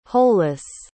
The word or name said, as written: holeless